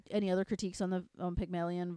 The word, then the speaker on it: Pygmalion: Pygmalion. – Pygmalion.